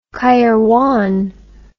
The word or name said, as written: qairwan